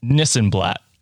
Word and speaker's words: Nissenblatt: It's Nissenblatt. It sounds exactly as it's spelled. – So before we have you on the show, Nissenblatt. – That is correct.